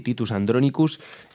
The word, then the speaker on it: Titus Andronicus: Titus Andronicus, titulada My Time Outside the Womb, donde veremos que tampoco son tan punks.